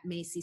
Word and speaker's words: Maisie: under my name at Maisie Soetantyo.